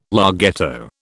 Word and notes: larghetto: British English

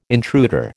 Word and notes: intruder: American English

